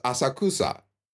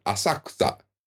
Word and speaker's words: Asakusa: When you look at it, it looks like it says Asakusa. But that middle syllable is almost silent. Asakusa. The ku is silent.